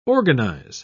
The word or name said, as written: organize